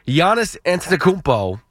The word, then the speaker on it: Giannis Antetokounmpo: Giannis Antetokounmpo will be on the New York Knicks within the next two seasons.